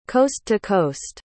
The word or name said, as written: coast-to-coast